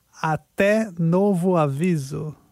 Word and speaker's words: até novo aviso: So, until further notice, you can say, até novo aviso. – Até novo aviso. Yes. Até novo aviso. That is how you say until further notice.